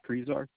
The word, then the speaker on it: Krizar: Nicholas Kreezar. – How do you spell that, Nicholas? Is that your last name? – K-R-I-Z-A-R.